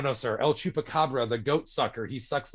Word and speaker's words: Chupacabra: And so to try and instruct a New Yorker how to say El Chupacabra was really funny, especially trying to get you to roll your R's, because that's not something a normal person knows how to do.